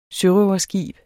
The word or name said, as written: sørøverskib